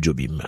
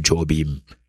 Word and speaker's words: Jobim: Il est couramment appelé Tom Jobim, hein, qui se prononce en portugais Tom Jobi, et non à l'américaine comme on l'appelle généralement Tom Jobim.